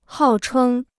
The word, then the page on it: 号称: hào chēng